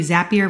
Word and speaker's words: Zapier: How about Zapier? That is like, or maybe it's Zapier. I think it's supposed to be Zapier rhymes with happier.